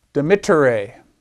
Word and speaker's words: dimittere: So you pronounce that dimitto, dimittere. It's not dimittere, dimittere. It's a short vowel.